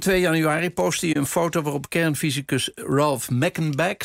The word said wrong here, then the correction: Meckenbach: zeg ik het goed? Of is het Meckenbach? – Ik geloof dat. – Meckenbach, ja.